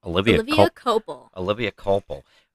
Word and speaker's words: Olivia Culpo: And a prime example of this recently was, I don't know if I'm going to say her name wrong, Olivia Culpo. – Olivia Culpo was a former, I believe, Miss USA.